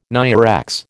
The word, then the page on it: NIREX: American English